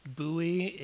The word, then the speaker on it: Bowie: So, well, uh, it's it's definitely pronounced Bowie in Bowie, Texas. So I don't I don't know how they pronounce it elsewhere, but they definitely call it Bowie.